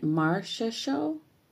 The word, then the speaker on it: Marchessault: Marchessault.